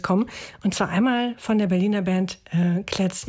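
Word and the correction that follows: Klez.e: Jetzt wollte ich dich vorher noch fragen. – Klesi. – Klesi, genau. Ich habe schon wieder vergessen, wie sie ausgesprochen werden.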